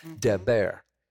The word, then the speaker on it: Debir: So he's talking about raising his hand towards the holy place, and there, that word is, I raise my hand towards the Deber, D-E-B-I-R. It's pronounced like Deb, D-E-B, air, A-I-R, Deber.